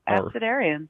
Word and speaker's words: Abecedarium: That's exactly right. Abecedarium.